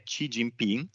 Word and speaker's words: Xi Jinping: Ecco, il eh, potere dell'attuale leader cinese eh, la cui pronuncia sono andato a cercarmela su internet perché era una cosa che mi incuriosiva, che è Xi Jinping,